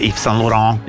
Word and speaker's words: Yves Saint Laurent: Ahora, Yves Saint Laurent, o no sé cómo se dice, Yves Saint Laurent.